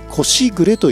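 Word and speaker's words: 蚕時雨: お蚕が桑の葉をむしゃむしゃと一斉に食べるときに生じるさあパラパラと小雨が降っているように聞こえる心地よい音のことを腰暮れ、蚕のしぐれと書いて腰暮れというそうです。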